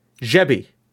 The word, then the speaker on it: Gebbie: G-E-B-B-I-E. – That sounds like Gebby to me. – Jebby. It's pronounced Jebby. I was calling him Gabby. – There's no A. – E's can sound like A's.